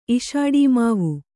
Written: ಇಶಾಡೀಮಾವು

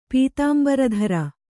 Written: ಪೀತಾಂಬರಧರ

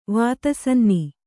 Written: ವಾತಸನ್ನಿ

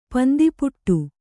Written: ಪಂದಿಪುಟ್ಟು

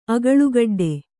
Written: ಅಗಳುಗಡ್ಡೆ

